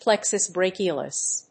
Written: plexus brachialis

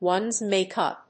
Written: one's make‐up

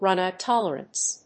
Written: runout tolerance

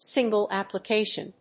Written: single Application

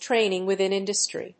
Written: training within industry

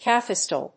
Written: cafestol

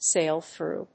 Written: sail through

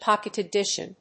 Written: pocket edition

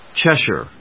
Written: Cheshire

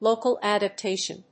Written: local adaptation

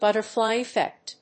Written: butterfly effect

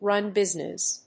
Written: run business

